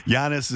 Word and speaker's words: Giannis: Do you think that Giannis is, Sid was giving me the thumbs up in the pronunciation.